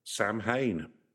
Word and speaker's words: Samhain: Samhain, yeah. – Samhain, yeah. – Samhain, if you're American. – Samhain. – It was Gaelic in it, so it's spelled completely different to how phonetically it's.